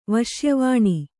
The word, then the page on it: ವಶ್ಯವಾಣಿ: kannada